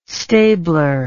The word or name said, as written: stabler